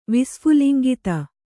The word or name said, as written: ವಿಸ್ಫುಲಿಂಗಿತ